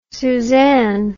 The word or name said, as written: susann